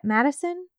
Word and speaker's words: Matteson: This case stated that he was arrested in a motel room in Mattison. Is that how you say it? Mattison? M-A-T-T-E-S-O-N. – Oh, yeah, for sure.